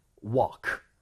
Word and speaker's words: walk: L 발음을 아예 하지 마세요. 그냥 walk. Walk 한번 해보세요. – Walk. – Mm-hmm. – Walk. – 그렇게 하셔야 돼요. 이거 안 그러면 work랑 헷갈리니까.